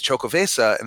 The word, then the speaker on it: Xocoveza: With Chocovesa, and it's spelled Xocoveza.